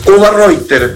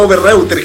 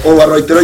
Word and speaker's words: Overreutier: Es Overreutier, así como se pronuncia originalmente. Yo, de hecho, también lo digo mal. Yo le digo Overreutier generalmente, pero se pronuncia Overreutier.